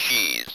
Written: cheese

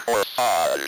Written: corsage

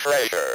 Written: treasure

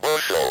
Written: bushel

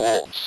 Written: wolf's

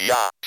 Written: yacht

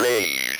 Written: beige

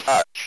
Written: touch

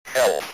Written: health

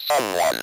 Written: someone

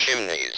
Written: chimneys

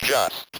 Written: just

